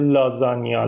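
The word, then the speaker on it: lasagna: این کلمه توی زبان ایتالیایی جی اینه، مثل لازانیا، لازاگنا نوشته میشه، یا بولونیا، بولوگنا.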